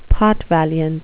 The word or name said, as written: potvaliant